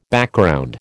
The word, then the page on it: Background: American English